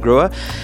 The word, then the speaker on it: Grohe: This week's episode is brought to you by Groa, the maker of innovative faucet and showering products. It's spelled G-R-O-H-E, even though it is pronounced Groa.